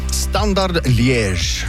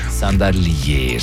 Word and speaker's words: Standard Liège: Standard Liège. – Standard Liège.